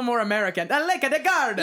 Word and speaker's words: Garda: can you say it like the locals do? – Garda. – They say Garda.